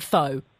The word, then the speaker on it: pho: But at least you pronounce it correctly, because it's spelt pho, so most people do call it pho.